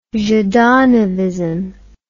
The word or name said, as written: zhdanovism